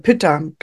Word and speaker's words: Pütter: Genau, und ansonsten gibt es natürlich verschiedene Kompressionsmöglichkeiten und verschiedene Arten, Kompressionsverbände zu machen, zum Beispiel nach Fischer oder der Kompressionswickelverband nach Püttner oder der Modif modifizierte Kompressionsverband nach Püttner. Aber Pütter. Das macht man oft, Pütter, genau, verkehrt ausgesprochen, Pütter.